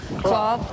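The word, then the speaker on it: Klaw: So we were talking before about the wild, outrageous photos of Irving and Paula Clough that you did. Am I pronouncing it right? Is it Claw or Clough? – Claw.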